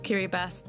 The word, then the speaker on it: Kiribati: Yes, it is pronounced Kiribati. That's how it's pronounced in the local language Gilbertese.